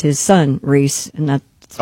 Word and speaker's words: Rhys: Does he pronounces it Rhys? – Rhys. – Does he? I don't know. – Oh, it is Rhys.